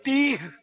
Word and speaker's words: تیہ: میدان تیا میں تیا, تیا نہیں تو تیا, وہ اصل تلفظ ہے تیہ, تیا نہیں ہے تیہ, یہ یاد کر لیں.